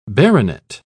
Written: baronet

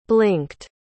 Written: blinked

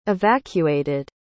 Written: evacuated

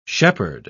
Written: shepherd

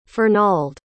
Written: fernald

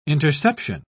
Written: interception

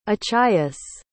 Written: achaius

